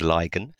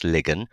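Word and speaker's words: Lygon: His name was William Ligon, and another spelling coming up here, it's L-Y-G-O-N. I always thought it was Ligon, but it's pronounced Ligon.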